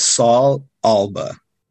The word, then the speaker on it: Saul Alba: Okay, so uh, my name is Saul Alba.